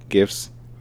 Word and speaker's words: GIF: Because it's G. It's not J. GIF. – GIF? – GIFs. – Okay. – GIFs. GIFs. Yeah, we're going with GIFs.